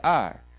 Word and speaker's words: Ai: Now, on the other hand, Joshua sent men from Jericho to Ai, that's pronounced Ai, which is near Beth Aven, to the east of Bethel, and told them, Go up and spy out the region.